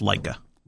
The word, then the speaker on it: Laika: What's funny about the, I don't know how to pronounce it, the dog's name. It's L-A-I-K-A. – I think that's Laika.